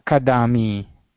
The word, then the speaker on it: kadami: They are equally emphasized, Mahodaya. I know in English, every accent of English has a certain emphasis. Mm-hmm. And in general, American English has a second syllable emphasis. – Mm-hmm. – So what does that mean? In English, American English particularly, if they see a word like that, if they see K-A-D-A-M-I, they'll say kadami.